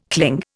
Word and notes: cling: Indian

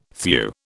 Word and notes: thew: British English

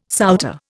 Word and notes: souter: Indian

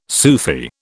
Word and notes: Sufi: British English

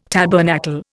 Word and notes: tabernacle: Indian

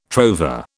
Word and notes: trover: British English

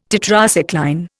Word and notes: Tetracycline: Indian

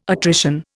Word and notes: attrition: Indian

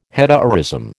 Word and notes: hetaerism: American English

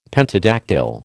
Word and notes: pentadactyl: American English

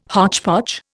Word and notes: hotchpotch: Indian

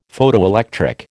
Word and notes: Photoelectric: American English

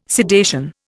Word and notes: sedation: Indian

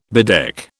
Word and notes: bedeck: British English

